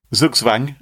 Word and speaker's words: Zugzwang: Zugzwang? – Zugzwang.